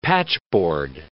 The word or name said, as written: patchboard